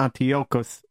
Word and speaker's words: Antiochus: And if you ask on internet actually how to pronounce Antiochus, they disregard the CH and they put in the K over there, Antiochus. So it sounds weird.